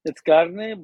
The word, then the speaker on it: carne: We don't say it like that. Carne. It's carne, but you said carne.